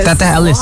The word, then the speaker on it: Teteh Elis: How do you say it? – Teteh Elis. – Wow. What is it? Why? – It's a sweet nickname meaning big sister in Sundanese culture.